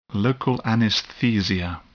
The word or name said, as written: local anesthesia